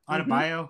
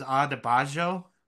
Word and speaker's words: Adebayo: Is that how you say it? Adebayo? – Mm-hmm. Yeah. Mm-hmm. – For some reason, I've always thought it was Adebayo, like the Y makes a Z sound.